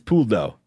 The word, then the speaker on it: Pouldeau: The correct answer is Puldo. – Is that how you spell it? – You're close. It is P-O-U-L-D-E-A-U.